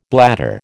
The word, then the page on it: bladder: American English